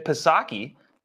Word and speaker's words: Psaki: You know, he should be used to having a silent P, as Jen Psaki is his press secretary, has a silent P at her in front of her name.